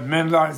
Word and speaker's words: Menla: But Menla is the Tibetan one, which is one, just two syllables, and it fits because it comes through Tibet, the whole thing, to the kindness of Tibet.